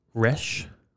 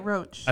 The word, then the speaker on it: Resch: Resch. – How do you get Roach? – I don't know.